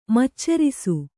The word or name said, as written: ಮಚ್ಚರಿಸು